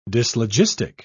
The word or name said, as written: dyslogistic